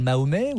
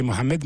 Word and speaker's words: Mohamed: C'est Mohamed, mais comme les islamologues, les premiers islamologues étaient des islamologues occidentaux, ils n'arrivaient pas à prononcer le H, le H, si vous voulez, guttural. Donc ils ont simplifié en disant Mahomet, encore qu'au Moyen-Âge, on disait Mahomet ou Mahomet.